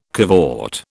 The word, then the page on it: cavort: British English